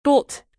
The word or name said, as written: dolt